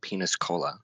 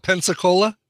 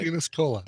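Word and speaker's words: Peñíscola: And then there's a city in Spain called Peniscola. – Not Pensacola? – No, it's not spelled like that. – All right. – Peniscola.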